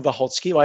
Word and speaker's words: Wachocki: So I think you did quite well. It's Jakub Wachocki. I have spent 11 years in Italy, and believe me, there were a variety of pronunciations associated with that surname.